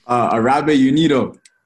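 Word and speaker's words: Árabe Unido: But looking back at your kind of debut against, I'm going to butcher the name, but Araba Undu? – Araba Unido. – Yeah, there it is.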